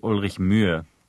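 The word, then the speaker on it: Ulrich Mühe: I mean, the sound, which is in his last name, doesn't actually exist in the English language. So it's actually Ulrich Muhe.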